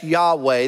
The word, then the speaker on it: Yahweh: Anytime L-O-R-D is in your Bible in all caps, that's Yahweh. That's Y-H-W-H. Yahweh's the best we can do to pronounce it, okay?